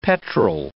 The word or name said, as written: petrol